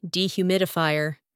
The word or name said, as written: dehumidifier